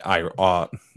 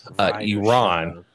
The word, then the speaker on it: Iran: has to beat Iran. My bad. Let me say it right so that, another reporter doesn't get mad at us. Iran.